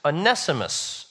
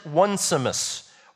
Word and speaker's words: Onesimus: When you see it spelled out, though, don't pronounce it Onesimus or Onesimus. Even though that's funnier, that's not probably how we would pronounce it.